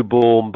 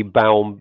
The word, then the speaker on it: Baum: It's, it's Andrew Baum, by the way. It's a very difficult name to pronounce. Um, it was probably it? originally Baum, but it's now Baum.